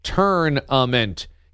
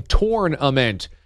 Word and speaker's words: tournament: Everyone says turn-a-ment. He's out here saying torn-a-ment.